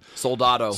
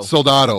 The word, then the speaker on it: Soldado: So now they've got the second one, Sicario Day of the Salando. Salando, I think is how you say it? – Soldado. – Soldado. There you go.